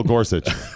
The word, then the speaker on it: Gorsuch: Neil Gorsuch. Sorry, did I say it wrong? – No, I was just going to say excuse you.